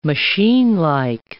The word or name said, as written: machinelike